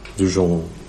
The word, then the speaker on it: Duchamp: Duchamp gjorde väl den där pissoaren.